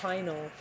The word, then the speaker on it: pineal: The pineal gland, pineal. Actually, I heard it. I'm gonna look it up. It was saying that the correct pronunciation, correct pronunciations, but pineal, it's actually pineal.